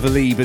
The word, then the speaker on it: Lieb: And one of the guys I was into is a German producer called Oliver Lieb, L-I-E-B. Oliver Lieb. Only I pronounced it Lieb. Incorrectly, because I wasn't too familiar and well-versed with German surnames back in the day.